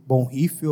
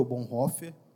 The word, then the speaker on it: Bonhoeffer: A gente está nessa série desse livro, Vida em Comunhão, do Dietrich Bonhoeffer, ou Bonhoeffer, qualquer um dos nomes está certo, que é alemão, então para pronunciar em português tem uma dificuldade mesmo.